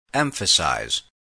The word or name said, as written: emphasize